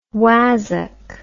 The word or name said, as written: wazzock